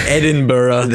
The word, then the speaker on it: Edinburgh: So, without further ado, we're going to be talking tonight about the world's most haunted cemetery, Greyfriars Kirkyard in Edinburgh, Scotland, or the Scots pronounce it, Edinburgh.